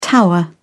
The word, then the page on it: tower: British English